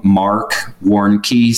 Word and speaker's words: Warnke: If they Google anything to do with my name, Marc Warnke, so it's M-A-R-C, so Mark with a C, and then last name is spelled W-A-R-N-K-E.